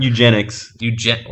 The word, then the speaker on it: eugenics: Eugenics.